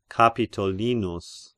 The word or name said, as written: Capitolinus